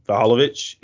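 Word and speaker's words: Vlahović: Vlahovic, is that it? – Yeah. Close enough.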